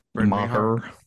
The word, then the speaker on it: Maher: How do you say his name? – Brett Maher. – Maher. Okay, Brett Maher.